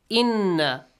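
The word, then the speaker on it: إِنَّ: Inna Rabbaka. The word inna has a noon mushaddad. Whenever you have noon mushaddad or mim mushaddad, you should pronounce it with a nasal sound.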